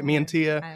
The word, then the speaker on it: Mantia: I don't know uh, what's going on, but um, one of the kids, he's like in his 30s now, Joey uh, Mattia, I think Mantia, is how you say it, Mantia.